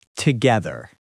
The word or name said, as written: together